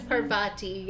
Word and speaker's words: Parvati: I thought it was Pavarti. Was it Pavarti? Yeah. It was Parvati.